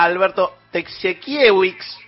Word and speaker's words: Alberto Tekciewicz: Y tenemos en línea a Alberto Tekciewicz, Coordinador de Desarrollo e Investigación ahí. ¿Lo dije bien, Alberto? Buen día, Horacio Marmolejo. – Sí, perfecto. Muy buenos días.